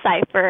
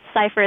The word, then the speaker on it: Cypher: Leilani Cypher, and Cypher is spelled C-Y-P-H-E-R.